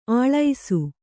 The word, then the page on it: ಅಳಸ: aḷasa